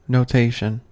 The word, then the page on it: notation: American English